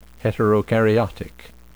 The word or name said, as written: heterokaryotic